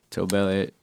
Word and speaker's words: Tobelliot: Tobelliot.